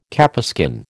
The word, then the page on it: capeskin: American English